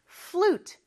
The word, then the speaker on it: flute: 자, 우리는 플루트라고 하는데 flute 하나의 음절로 발음해 주세요.